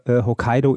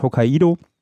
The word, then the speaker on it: Hokkaido: Ich glaube, Hokkaido ist die landestypische Aussprache und eingedeutscht, ist Hokkaido.